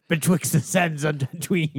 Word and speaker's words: Tatooine: But that reminds me, he was born on Tatooine. But in the Boba Fett premiere, he says, Tatooine. He's in the sands of Tatooine. – He smushed it together. – He does. – He's skipping a syllable.